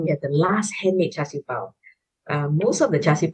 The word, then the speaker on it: char siu pau: Tell me about, on the street food tour, tell me about the steam buns, the char siu pau. – Ah, well pronounced, friend. The char siu pau.